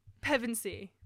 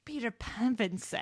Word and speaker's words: Pevensey: Peter, like, Pensieve. How do you say their last name? – Pevensey. – Peter Pevensey.